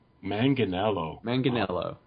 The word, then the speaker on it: Manganiello: Joe Manganiello. – Okay. It's a hard G. – Yes. – Okay. – I learned that the hard way. – Oh, okay. – Manganiello.